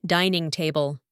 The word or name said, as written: dining table